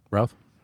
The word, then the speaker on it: Routh: Routh? – Is that how you say it? – Yeah. – Routh? – He's doing okay.